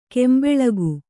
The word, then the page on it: ಗುಂಬಳಕಿ: Kannada